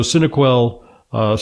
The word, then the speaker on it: Synaquell: It's pronounced Synaquell, not Synaquell, because it refers to the synapses, which are the interconnections between the neurons in the brain.